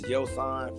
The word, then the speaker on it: Yoson: The correct spelling and pronunciation of my name is Yoson, first name. Yoson.